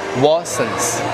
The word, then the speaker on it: Watsons: 叫 Watsons， Watsons，那个 T 不读不发音啊， Watsons， Watsons，我就敷个面膜了。